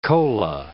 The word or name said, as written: cola